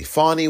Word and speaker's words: Fani: Fani Wells, I should pronounce her name properly. Fani Wells, F-A-N-I.